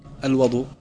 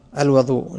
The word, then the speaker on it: الوضوء: الوضوء. – الوضوء، نعم. – قال: أحدها الوضوء بفتح الواو اسم للماء